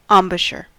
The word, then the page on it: embouchure: American English